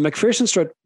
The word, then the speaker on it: MacPherson: Is that the right way to pronounce it? – MacPherson strut. So I mentioned already that the wheel upright is connected at the top and at the bottom by the control arms.